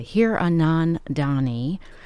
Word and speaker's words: Hiranandani: Acclaimed author Veera, I'm going to pronounce her name correctly, Hiranandani, has a new book out called AMIL AND THE AFTER.